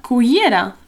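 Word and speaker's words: cullera: No, una cuillera. Yeah, it's got, again, that double L, y sound, which is quite difficult. But you could just say, you know, cuillera, cuillera.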